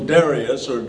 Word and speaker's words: Darius: It says, so Darius is a better pronunciation.